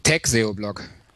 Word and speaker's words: Tagseo-Blog: Heißt es jetzt äh, Tagseo-Blog oder äh, Tagseo-Blog? Wie hätte du dich denn gerne ausgesprochen? – Äh, Tagseo-Blog. Also englische äh, Variante.